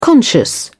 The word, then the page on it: conscious: British English